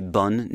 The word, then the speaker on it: bon: The masculine is the word B-O-N, bon, and then the feminine has a double N and an E, B-O-N-N-E. And so the difference is bon and bun, bon and bun.